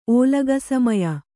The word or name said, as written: ಓಲಗಸಮಯ